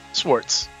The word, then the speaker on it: Swartz: Did I say your name right, or is it Swartz? – Swartz. Yeah. – Swartz. All right. – It's German, but not too German.